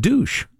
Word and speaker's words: Deutsch: Deutsch, who is one of the top. – it's pronounced douche.